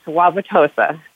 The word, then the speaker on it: Wauwatosa: Was it Wauwatosa? – Yeah, it's Wauwatosa.